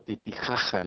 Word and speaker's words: Titicaca: Y fíjate que uno de los lugares a los que fui fue el lago Titicaca, como tú sabes, o bueno, Titijaja, ¿no? Como lo pronuncian la gente local.